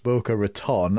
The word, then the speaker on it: Boca Raton: I know Mara lives in Florida, and it's pronounced Boca Raton, and you messed up the pronunciation, and that makes me think that you've read that one rather than making it up, or that could be a double bluff.